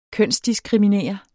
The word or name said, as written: kønsdiskriminere